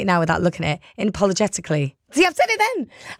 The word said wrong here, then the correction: unapologetically: I said it wrong. I said, I'm just getting... The producer in my ear saying it's in... Unapologetically.